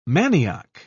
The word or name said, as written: manioc